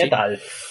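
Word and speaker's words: Hnefatafl: mirar un poco cómo se pronunciaba y yo al principio lo pronunciaba Netalfi. Porque pensaba que esa L era una I latina, tío. Claro, tío, es que se escribe H-N-E-F-A-T-A-F-L. O sea, ¿cómo pronuncias eso, por Dios?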